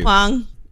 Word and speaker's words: Huang: Huang.